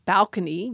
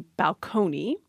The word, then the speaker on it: balcony: For example, balcony used to be balcony. And in the 19th century, Samuel Rogers really didn't like that. And he described the pronunciation balcony as enough to make him sick.